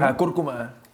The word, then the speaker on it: cúrcuma: Cúrcuma.